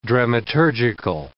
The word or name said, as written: dramaturgical